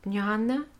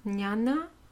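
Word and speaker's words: jñāna: In Sanskrit, the verb to know is jhana, which when it is pronounced a little softer, can start to sound like no, like jnana, jnana, no, no, right?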